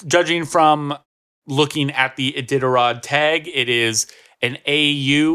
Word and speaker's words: Iditarod: Ita, how do you think it's pronounced? – Iditarod. – Iditarod? Iditarod? – Iditarod.